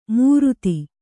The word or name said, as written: ಮೂರುತಿ